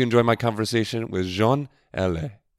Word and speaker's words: Allais: Yeah, not Allen. – Not Allen. Allen. Allen. – Allen. You don't pronounce the N. Allen. – Oh, Allais. – Yeah. – Oh, I've been saying the N the whole time.